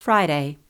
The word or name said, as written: Friday